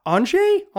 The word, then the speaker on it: Andrzej: A listener who translated their name as Andrew, but I'm gonna give the Polish pronunciation my best shot here and call it Andrzej. It's kind of like Andre.